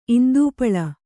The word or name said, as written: ಇಂದೂಪಳ